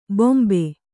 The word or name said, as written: ಭಂಭಾ